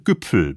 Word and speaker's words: Güpfel: Bayerisch ausgesprochen Güpfel bzw. Gipfel.